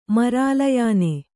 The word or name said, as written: ಮರಾಲಯಾನೆ